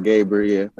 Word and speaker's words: Gabriel: It seems like, to a degree, we're starting to get that now with yourself, and I believe, the linebacker, Gaboyer, I believe his last name is. I'm going to butcher that last name. – Yeah. Makai Gabriel. – Yep. There you go.